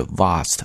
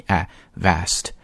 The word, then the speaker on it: vast: 但注意一下这个单词的读音，英式英语读的是 vast 发 r 这个音，而美式英语呢发梅花音 i vast。